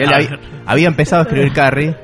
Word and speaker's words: Carrie: Carrie, para los que no saben pronunciarlo.